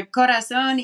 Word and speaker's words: Corazón: We are going to play Free Man right now from Bree's new record called Corazón. Say it properly for me since you have a much prettier accent than I do. – I think you're doing a great job. It's a Corazón y Cabeza.